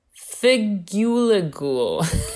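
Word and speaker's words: Figugegl: But so from the ubiquitous campaigns mainly funded by the Swiss Cheese Union made to promote the dish comes a tongue twisting word, figuligule. Mm-hmm. It's spelled figuegl.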